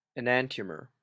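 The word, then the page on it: enantiomer: Canadian English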